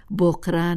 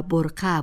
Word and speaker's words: برقه: پس به خاطر داشته باشیم که بقره یک تلفظ عامیانه از کلمه کهن و عربی برقه است و فرهیختگان جامعه بهتر است بکوشند آن را به صورت درستش به کار برند.